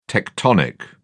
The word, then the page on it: tectonic: British English